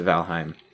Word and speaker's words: Valheim: I'm still conflicted on how to pronounce that game. – Well, most people pronounce it Valheim.